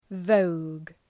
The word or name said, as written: vogue